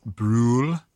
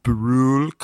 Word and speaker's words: Brühl: Daniel Brühl. – One more time. – Brühl, comma Daniel.